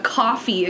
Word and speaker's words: Kofi: I made us a coffee account, which it's like... – Not C-O-F-F-E-E. – It's spelled K-O-F-I, if you guys know what coffee is.